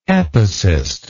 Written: epicist

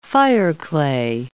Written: fireclay

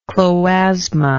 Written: chloasma

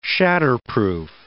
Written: shatterproof

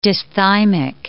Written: dysthymic

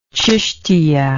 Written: chishtiya

